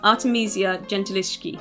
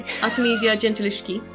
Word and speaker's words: Artemisia Gentileschi: So, I'm going to be talking about Artemisia Gentileschi.